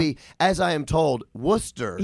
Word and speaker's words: Worcester: It's like Worcestershire, like nobody knows really how to say it the right way.